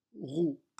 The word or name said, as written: roue